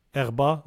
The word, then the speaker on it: herb: Now, in French, we wouldn't say the H. It's a silent H, herb. Grass, green crops, herb. Although H-E-R-B has always been spelled with an H, pronunciation without it was usual until the 19th century and is still standard in the U.S.